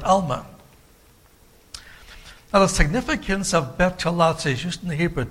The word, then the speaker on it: Betulah: I'll spell it for you, B-E-T-U-L-A-H. B-E-T-U-L-A-H, Betulah.